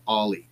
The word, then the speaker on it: Aller: It's spelled A-L-L-E-R, but I was told it's pronounced Ollie.